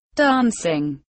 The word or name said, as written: dancing